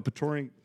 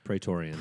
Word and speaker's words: Praetorian: What if a Praetorian... I always say that wrong. – Praetorian.